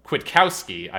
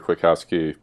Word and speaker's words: Kwiatkowski: Pete Kwiatkowski, I believe is the correct pronunciation. Not going for my full-on true Polish there.